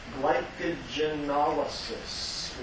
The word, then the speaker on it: glycogenolysis: Be ready to call it glycogenolysis, or if you want to say that more slowly and pronounce it a little bit differently so that you hear the breakdown of glycogen and lysis of glycogen, say glycogenolysis.